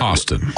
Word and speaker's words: Hostin: Hostin.